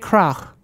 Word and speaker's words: Krach: My last name is German, so the C-H in my last name is pronounced as the H in hot. Yeah. Fede Krach.